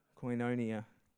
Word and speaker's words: Koinonia: Koinonia.